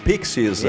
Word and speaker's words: Pixies: Io ho scelto i Pixies. Ehi. Ma si pronuncia così. Sì, sì, va bene così.